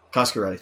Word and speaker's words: Coscarelli: Having said that, this is like Don Coscarelli's, am I saying that correctly? – Coscarelli, thank you.